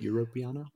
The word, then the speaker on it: Europeana: It's Europeana. Hopefully I'm saying that right. By Patrick Rednick. Is that how you say it? Europeana? – Yes, sure.